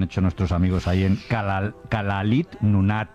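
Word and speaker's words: Kalaallit Nunaat: Nada, allí cuando juntan las dos L's le ponen una S y una L. Kalalit Nunaat. – Igual que lo he dicho yo. Kalalit Nunaat. – Kalalit Nunaat.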